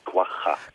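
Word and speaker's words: quagga: De, de Kuwaga, ik weet niet eens eigenlijk of ik het goed uitspreek. – Ja, dat spreek je helemaal goed ja. – uit. Uh, denk je van het is kwaga, maar het is kwaga.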